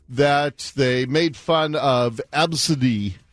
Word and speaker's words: Abcde: So we told you the story yesterday about the mom who named her daughter A, B, C, D, E. – Mm-hmm. – Pronounced Absidy. Absidy.